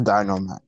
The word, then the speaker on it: Dynamax: How Trial was is Dynamax. I think that's how you say it. I'm saying it right. – Yes, it's Dynamax.